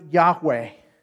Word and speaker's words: Yahweh: Probably you may have heard that the Hebrew word there is, well, the best we can do with it is we pronounce it Yahweh, right?